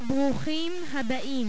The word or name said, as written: brukhim